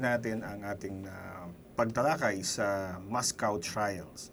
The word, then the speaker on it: Moscow: Moscow. Wala W. Moscow.